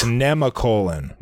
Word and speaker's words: Nemacolin: Also, you're pronouncing Nemacolin wrong. – Ooh. – It's Nemacolin. – Nemacolin, okay. – Not Nemacolin. Thank you, Catherine.